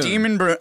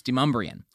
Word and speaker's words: Demonbreun: It just means you haven't been to town long enough to pronounce it the way it's supposed to be, which is Demumbrian.